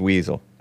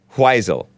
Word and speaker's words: Wiesel: It's pronounced weasel. Oh, it's pronounced weasel, says Will Cole. That sounds, I'm not going to say it. Weisel. Eli Weisel, says GMD Jim.